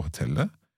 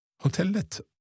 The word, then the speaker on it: hotellet: Vi har två nätter kvar på hotellet. Hotellet eller hotelle? – Hotellet. – Hotellet, so you always pronounce that T in the end.